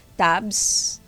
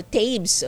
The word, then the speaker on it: Tabes: Tapos sa women, ang kanilang goal ay sana maka-top 4 under Coach Tabs. Okay, Tabs ba? Or Tabes, okay. Parang waves, Tabes. Ganun pala ang pronunciation, no?